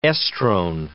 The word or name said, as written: estrone